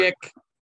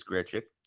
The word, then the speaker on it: Grichuk: I know he loves fucking mispronounced words. – Grichuk. – Thank you. He's finally caught on. It is Grichuk.